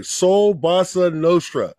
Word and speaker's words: Soul Bossa Nostra: Soul Bossa Nostra.